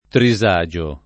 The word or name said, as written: trisagio